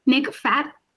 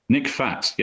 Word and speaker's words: Fajt: How do we pronounce Nick's name at the CEO of Rec, Rec Room? – Nick Fajt. – Nick Fajt, yeah, um, spelled F A J T.